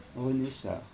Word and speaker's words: René Char: René Char.